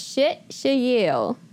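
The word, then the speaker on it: shit shayil (אשת חיל): That actually virtuous woman comes from the Hebrew word, and I practice this a lot, and I'm going to try to say it right. It's shit shayil, okay?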